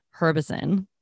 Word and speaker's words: Herbison: Herbison. The H is not silent.